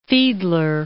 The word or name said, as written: Fiedler